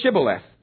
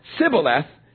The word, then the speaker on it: Shibboleth: They said, okay, then say Shibboleth. And if he said Sibboleth, because he couldn't pronounce the word correctly, they seized him and killed him at the fords of the Jordan.